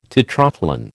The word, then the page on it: tetrathlon: American English